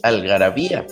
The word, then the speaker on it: Algarabía: Para empezar, es Algarabía, porque tiene acento en la I.